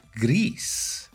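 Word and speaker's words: Gris: I believe it's called Greece. – You are correct with your Spanish pronunciation.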